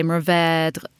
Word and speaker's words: Mourvèdre: If you're going to say it, I guess, as a Texan, but in the French would say Mourvèdre with a little bit of a accent on the end.